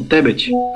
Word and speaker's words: Tebet: Esse sobrenome aí é, é Tebet, Tebé, ou como é que como é que pronuncia esse? É, é isso aí mesmo, Tebet.